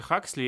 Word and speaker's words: Хаксли: А еще фамилия у них правильно читается Хаксли.